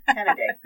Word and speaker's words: Cannaday: Is that Cannaday? – Cannaday. Cannaday. Googled how to pronounce it. Cannaday.